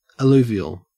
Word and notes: alluvial: Australian English

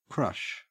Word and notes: crush: Australian English

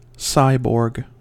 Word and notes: cyborg: American English

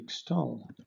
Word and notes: extol: British English

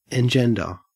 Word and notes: engender: Australian English

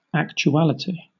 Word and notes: actuality: British English

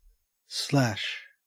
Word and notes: slash: Australian English

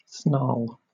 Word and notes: snarl: British English

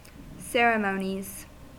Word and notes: ceremonies: American English

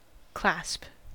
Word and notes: clasp: American English

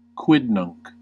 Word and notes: quidnunc: American English

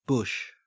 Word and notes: bush: Australian English

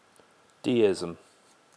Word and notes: deism: British English

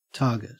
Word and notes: target: Australian English

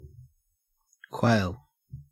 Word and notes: quail: Australian English

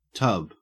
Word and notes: tub: Australian English

